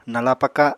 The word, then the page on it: nala paka: Apatani